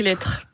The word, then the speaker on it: Lestre: Ça s'écrit Lestre. Et on dit Lettres.